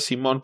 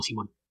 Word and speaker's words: Simone: Lo que pasó con Jim Lee, creo que lo escribió incluso con tilde, Beto, pero de hecho debería ser Simone. Pero dejémosla en Simón, porque así creo que lo pronuncia ella, me parece. – Uh-huh. Sí, ella lo pronuncia como Simón. Más suavecito que como lo pronunciamos en español. No tan marcado el acento al lado, pero sí con la última sílaba como la fuerte.